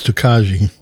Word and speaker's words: Tokaji: That's because you can pronounce it wrong. It's Tokaji.